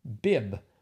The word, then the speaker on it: Bibb: Bib. – Bib lettuce, yes. – But it's spelled B-I-B-B.